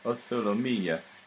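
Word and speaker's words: O sole mio: And notice I say O Solo Mia because although it's spelled M-I-O, the Neapolitans would have pronounced it O Solo Mia.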